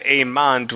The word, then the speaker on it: Aemond: By the way, it's weird on IMDb that so there's Aegon and then his younger brother Aemon, but they pronounce it Aemond with a D.